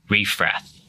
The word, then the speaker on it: Refrath: Sam also plays in the top flight league badminton in the German Bundesliga with TV Refrath. Did I say that right? – Yeah, that's correct.